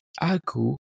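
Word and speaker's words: ačiū: For example, the words for thank you is asiu, pronounced as aku. Yes, it sounds like sneeze.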